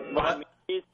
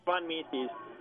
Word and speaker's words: Von Mises: You guys are, like, totally mispronounced. Von Mises. It's Von Mises.